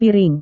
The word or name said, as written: piring